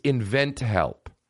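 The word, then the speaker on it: InventHelp: It's invent help. They had to put it on the screen while he was saying it, of course, because he says, invent here. Hey, say it right.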